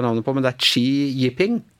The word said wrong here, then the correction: Xi Jinping: Xi Jinping, sier jeg i hvert fall.